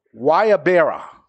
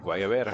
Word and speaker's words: Guayabera: Guayabera. Pretty good. – Guayabera.